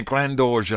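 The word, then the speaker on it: Graindorge: Before that we had something from Catherine Grandorge, or Grandorge, Catherine Grandorge, I think is how you should pronounce that, and that's featuring Iggy Pop.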